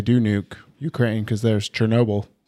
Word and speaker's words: Chernobyl: I know a lady from that area, and she was laughing when I said Chernobyl. She said, it's Chernobyl.